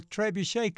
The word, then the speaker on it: trebuchet: Citadel Trebuchet competition. Did I say that right, Jennifer Albert, who is the STEM director at the Citadel? – Yes.